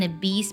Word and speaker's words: obese: 然后这个人就回答 You called me an obese penguin. O-B-E-S-E 这个单词可以读作 obese，也可以读作 obese，它指的是肥胖的，过胖的。